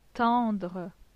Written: tendre

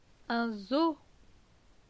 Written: Un zoo

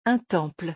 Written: Un temple

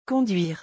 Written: conduire

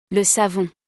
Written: Le savon

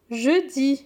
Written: Jeudi

